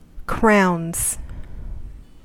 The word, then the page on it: crowns: American English